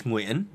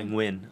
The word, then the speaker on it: Nguyen: In Vietnamese, it's Nguyen, but I go by Nguyen.